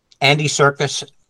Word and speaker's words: Andy Serkis: Andy Serkis, am I saying his name right? – Yeah.